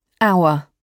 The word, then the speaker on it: hour: Le H est muet, c'est-à-dire qu'il ne se prononce pas. – Hour.